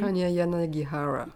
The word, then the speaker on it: Hanya Yanagihara: Hanya Yanagihara.